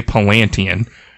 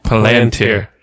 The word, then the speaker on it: Palantir: I always say Palantian. – Is that R on the end? – Palantir.